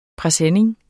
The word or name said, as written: PRESENNINGERNES